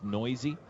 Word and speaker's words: Neuse: mispronounced his name, his last name. I believe he called him Neuse. – I bet Sheldon gets that a lot, or did. He and did. – One of his teammates who took the stage later said to the TV personality, by the way, his name is Noisy.